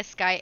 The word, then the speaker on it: Skye: Victoria Sky Erran. It's Victoria, like the queen, SKYE, last name Erran or Erran, whichever one you want to call, Erran, depending on the pronunciation you want.